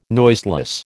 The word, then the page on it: noiseless: American English